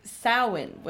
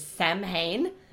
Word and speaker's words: Samhain: So, I always knew from what it looked like I was like, I knew how it's spelled, and I was pronouncing it for the past like 20 years, Sam Hain. and I've discovered this week I was this years old when I learned that it was Samhain, which is bad.